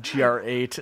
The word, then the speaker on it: GR8: If you didn't listen to the last episode, GR8, it was engine code, which pronounces phonetically to great.